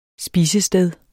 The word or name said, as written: SPISESTEDERNE